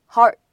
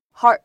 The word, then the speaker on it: hard: 当我们把 hard 后面呢加上一个 to，这个时候呢，你会发现有一个失去爆破的现象。我们应该怎么读呢？可以读成是 heart。 heart, heart.